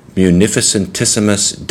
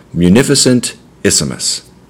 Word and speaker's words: Munificentissimus: So I do it because it's seven syllables, so you break it down into four and three. You gotta love Latin. Latin is the coolest language. Munificentissimus.